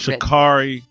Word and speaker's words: Sha'Carri: Help me understand why you are not on the side of, I think it's just Sha'Carri Richardson. Is that how you pronounce it? I've only seen it Sha'Carri, written. Sha'Carri Richardson.